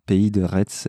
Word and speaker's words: Pays de Retz: la prononciation euh, en ce qui concerne le nom du projet, euh, c'est Pays de Retz. Voilà. Donc Pays de Retz, et de là, on, dérive facilement sur Pays de Retz, du coup, mais prononcé d'une seule traite.